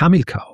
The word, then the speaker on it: Hamilkar: Und die Verwirrung ist naheliegend, weil Hannibal, spricht man so aus, Hannibal, Betonung auf der ersten Silbe, nur bei Hamilkar, da kommt eben, und das ist die lateinische Aussprache, ja?